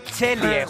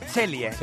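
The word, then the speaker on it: Celje: Se pronuncia Chelie. El ¿cómo? Equipo se pronuncia Chelie.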